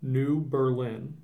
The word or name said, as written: New Berlin